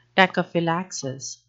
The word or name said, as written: tachyphylaxis